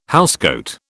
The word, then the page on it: housecoat: British English